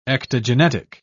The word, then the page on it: ectogenetic: British English